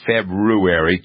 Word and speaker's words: February: February, my days as a school teacher bring me around to pronouncing that correctly. February, two R's in February, has nothing to do with the countdown except hopefully doing it the correct way.